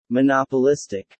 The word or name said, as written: monopolistic